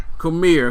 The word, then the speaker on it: Cormier: newly crowned heavyweight champion Daniel, how you pronounce his last name? – Cormier.